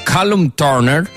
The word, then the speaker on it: Callum Turner: Lei, ricordiamo, si è sposata con quello che era il suo fidanzato, era suo marito Callum Turner. Questa è la pronuncia inglese, avete visto quanto sono madrelingua.